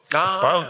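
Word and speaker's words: Poäng: And sometimes things from their everyday life, like ABBA, or the chair Poang, am I pronounced correctly? – One more time. – But Poang chair, this famous chair, like... Poang, okay, yeah, sorry.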